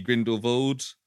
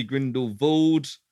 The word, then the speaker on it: Grindelwald: I think the implication there is that it is Grindelwald, but it's been anglicized because, you know, people don't understand how to pronounce a W as a V. So I think it is meant to be Grindelwald. – Yes, I think so. – Apparently, there's also a town in Switzerland called Grindelwald. – Oh, really? – Spelled, pronounced with the V. So it seems like it's probably Grindelwald, but people will use either. And that's my bit of fantastic trivia on where to find it.